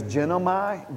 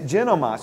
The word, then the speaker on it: ginomai: This word, the transliteration of it is genomai, G-I-N-O-M-A-I, if I'm pronouncing that correctly.